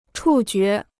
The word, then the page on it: 触觉: chù jué